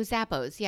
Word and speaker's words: Zappos: You don't pronounce the last S? No, Zappos. Yeah. Oh, Zappos. Uh, Isla Fisher Zappos. No, I said my friend Zappo. I made it one, but it is Zappos.